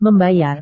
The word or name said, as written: membayar